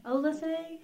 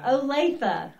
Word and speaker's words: Olathe: Olathe? What? Olathe. Olathe. Okay, now I remember. I thought it was Olath when I was first coming here. People call it that a lot, Olaf. – Yeah. – Olathe.